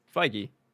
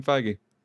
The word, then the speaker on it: Feige: Feige. Right? – Yeah, Kevin Feige.